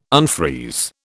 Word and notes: unfreeze: British English

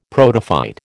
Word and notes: protophyte: American English

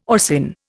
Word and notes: orcin: Indian

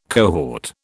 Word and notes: cohort: British English

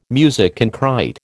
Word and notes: musique concrÊte: American English

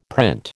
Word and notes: print: American English

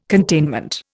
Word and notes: containment: Indian

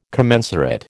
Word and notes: commensurate: American English

